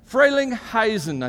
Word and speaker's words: Frelinghuysen: Frelinghuysen, I think is how you say it. Is that close? Is that the U-I gets you an I sound? Okay, U-Y. Thanks, Bruce.